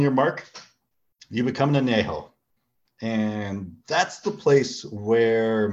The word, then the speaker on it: añejo: Do you mind saying it correctly? – Hang on, let me read the label here. I need some, where is it? – Añejo. – Añejo. – Thank you. That's all I'm saying. There. Let's pronounce things correctly on the show. We love to segue into things. I didn't mean to kill the guy. – No, it's okay. That was actually the correct dialect.